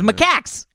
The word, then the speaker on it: macaque: This is macaques. And I didn't say that. You said it wrong. This is macaque. I was like, oh my God, somebody pick up macaque.